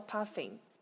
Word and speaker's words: Puffin: 但是我有一个东西，我真的当时超级喜欢，就是他们的冰岛国鸟，就是海鹦鹉，叫 Puffin。其实它念起来有点，对，它念起来有点像泡芙的发音。